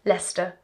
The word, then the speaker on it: Leicester: Got a picture of a napkin, and on the napkin they printed FYI for next season. It's pronounced Leicester.